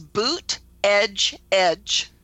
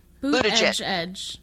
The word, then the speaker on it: Buttigieg: If you slow it way down, it's boot edge edge. – Buttigieg.